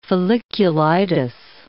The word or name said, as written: folliculitis